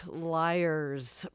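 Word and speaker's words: Lyres: Liars, pronounced like liars, but spelled for the musical instrument Liars.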